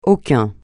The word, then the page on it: aucun: francais